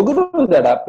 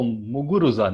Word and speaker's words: Muguruza: No, Muguruza, Oh, Muguruza, no? – Yeah, she's the defending champion, dude. Give her some respect. – She's actually pronounced Muguruza, I think.